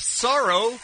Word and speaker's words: Saro: We have Sorrow in Long Beach. Hi, Sorrow. – Hey, good morning, guys. – Is that how you say your name? S-A-R-O? – Yeah, sorrow like sadness.